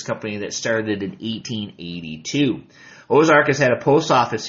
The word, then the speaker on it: Ozark: Ozark, well, originally was spelled A-U-X-A-R-C, but pronounced Ozark, meaning the bend.